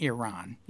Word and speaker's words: Iran: I guarantee he said Iran and not Iran. Iran is the proper pronunciation.